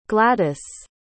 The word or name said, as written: gwladus